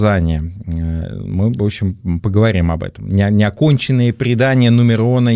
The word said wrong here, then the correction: Нуменор: и Нуменора, прошу прощения.